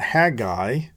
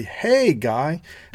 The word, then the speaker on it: Haggai: Now, some folks will call this Haggai or Haggai. I call it Haggai, which I think is correct. But if you're from the South, you may use two syllables and say, "Hey, guy."